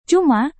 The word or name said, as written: cuma